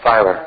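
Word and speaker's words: Feiler: And those like Bruce Feuer and other people, Feuer? I don't know how to pronounce his name. He's Filer.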